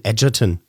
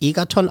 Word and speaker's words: Egerton: da hat er eben den jungen Taron Egerton kennengelernt. Der wird nicht Egerton ausgesprochen, sondern Egerton.